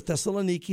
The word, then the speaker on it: Thessaloniki: or Thessaloniki is how they say it there in Greece.